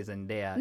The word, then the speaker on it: Zendaya: I'm going to say Zendaya. – No, it's the right pronunciation.